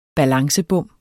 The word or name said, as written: balancebom